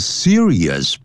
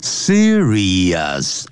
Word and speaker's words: serious: 那么，some of these substances，有些这些物质呢，can cause serious problems，会引起呢严重的问题啊，serious 啊，不要念成 serious，常常听到这，我们中国人常常会念成 serious 啊，serious 啊，serious。